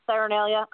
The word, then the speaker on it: Saturnalia: I can't even say it right. Is it Saturnalia? Am I saying that right? – Saturnalia. Uh huh.